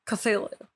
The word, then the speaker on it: Cthulhu: So Lovecraft transcribed the pronunciation of Cthulhu by saying the following. So the first syllable pronounced gutturally and very thick. Then the U is about that in full. And the first syllable is not unlike cool in sound, hinting the H representing the guttural thickness. Cthulhu.